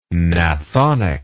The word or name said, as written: gnathonic